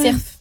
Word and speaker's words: cerf: cerf. – Ah oui, cerf. Ouais. Ah, dans le sud-ouest, on prononce toutes les lettres. – Et dis le F.